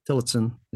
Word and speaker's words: Tillotson: When I hear that song, it's the original was Johnny Tillotson. Did I say that right? – Tillotson, yeah.